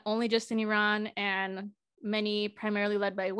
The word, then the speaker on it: Iran: I used to say Iran, but I think the correct pronunciation is Iran.